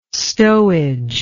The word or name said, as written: stowage